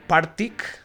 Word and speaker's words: Partick: Entonces llegaban estos dos provenientes de Escocia, Glasgow, eh, del Partick, eh, así es, no Patrick. Si alguno va a decir, ah, no lo pronuncio mal, no es Partick.